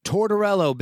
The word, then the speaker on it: Tortorello: How do you say your last name? Because I don't want to botch it. – Tortorello, big old Italian last name, a lot of syllables.